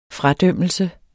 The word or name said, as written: fradømmelse